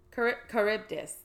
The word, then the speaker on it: Charybdis: Charybdis, I believe? Charybdis.